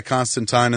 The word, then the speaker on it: Constantine: Hey, that's what Alan Moore says it's supposed to be pronounced, Constantine, Valentine.